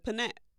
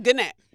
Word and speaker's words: gnat: It's like panat. – No. No, it's not. – It's not panat. One of them letters in there is silent. Oh, gnat. Gnat. It's gnat. I knew something was silent.